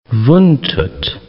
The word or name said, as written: Vuntut National Park